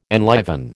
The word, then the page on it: enliven: American English